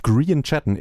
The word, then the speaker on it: Grian Chatten: Green Chatten, immerhin ihn habe ich richtig ausgesprochen, den Titel allerdings glaube ich nicht, denn es ist Fairlease, nicht Fairlies.